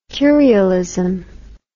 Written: curialism

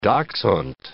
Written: dachshund

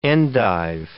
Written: endive

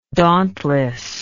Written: dauntlessness

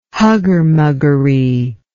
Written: huggermuggery

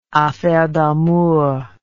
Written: affaire d'amour

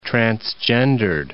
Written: transgendered

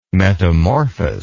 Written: metamorphous

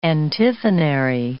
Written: antiphonary

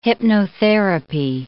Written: hypnotherapy

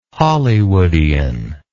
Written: hollywoodian

